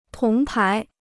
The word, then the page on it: 铜牌: tóng pái